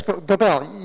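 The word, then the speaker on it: Gobeille: How do you say, Gobell case? – What? – The Vermont case? – Yes, Gobell. Yes, Your Honor.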